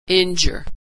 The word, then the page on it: injure: dzh